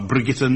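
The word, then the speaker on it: Brigitte: Hitching a lift home from the pub, stonemason Neville Thompson was picked up by Hollywood star Brigitta Nielsen. – She's Bridget, isn't she? – No, she's Danish. That's how you say it in Danish.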